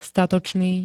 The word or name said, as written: statočný